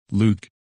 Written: Luke

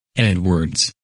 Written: Edwards

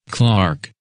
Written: Clark

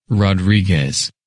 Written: Rodriguez

